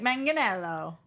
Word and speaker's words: Manganiello: No, people keep yelling at us, hard G, like Manganiello.